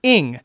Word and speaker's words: Eng: It's actually pronounced like ing, like ing. – English, you know. But I mean, you say Nicking, it doesn't sound right.